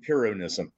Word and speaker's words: Pyrrhonism: So the pronunciation is Pyrrho and Pyrrhonism.